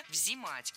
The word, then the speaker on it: взимать: Но меня смутило слово «взимать», потому что так говорить не рекомендуется. Правильно, «взимать». С гласной «и» в первом слоге мы и пишем, и произносим это слово «взимать».